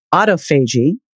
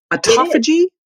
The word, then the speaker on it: autophagy: And I must tell you, until I heard you and Jeff Bland pronouncing it, I thought it was autophagy, but well, I guess it's autophagy. – No, it's both. It's like tomato, tomato, right? – Okay, good.